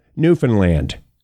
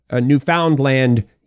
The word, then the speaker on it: Newfoundland: that is how most of us pronounce it, Newfoundland. If you want to go Newfoundland, you can, but that usually denotes, like, we'd be able to say, hey, you're from away.